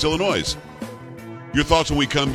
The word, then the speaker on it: Illinois: Thinks it's pronounced Illinois. – No. – That state next to Indiana is Illinois. You don't pronounce the S. – This is silent.